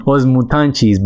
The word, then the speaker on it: Os Mutantes: I know you always pronounce it far better than me with your Portuguese. I'll let you do the honor. – Os Mutantes.